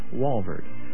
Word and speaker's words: Walvoord: And again, the speaker, Dr. John Walvoord. That's spelled W-A-L-V-O-O-R-D. Walvoord.